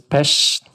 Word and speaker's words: Pest: So you say Budapest, and you have to do this Pest. Because that's the correct way of pronouncing.